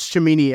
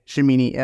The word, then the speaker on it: Cimini: Um, uh, Rich Cimini, actually, by the way, Cimini. Uh, I don't know how to pronounce it. It's a C. I think it makes an S sound.